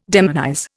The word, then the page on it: demonize: Indian